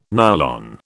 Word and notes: nylon: British English

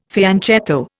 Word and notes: fianchetto: Indian

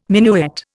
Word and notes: minuet: Indian

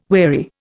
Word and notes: weary: Indian

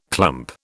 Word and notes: clump: British English

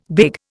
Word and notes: big: Indian

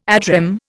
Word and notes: ad rem: Indian

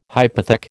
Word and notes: hypothec: American English

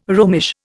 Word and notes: Romish: Indian

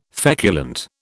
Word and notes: feculent: British English

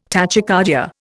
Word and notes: tachycardia: Indian

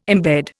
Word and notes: imbed: Indian

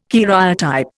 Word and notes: karyotype: Indian

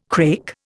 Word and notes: crake: Indian

